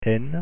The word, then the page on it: n: French